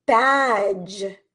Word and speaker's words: badge: And this D is silent. Okay. We do not say bad g or bad j. It is badge.